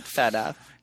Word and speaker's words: feta: Like Fita together. – Like, no, I don't want our feta together. – Like feta cheese. – Oh, I think you pronounce that feta.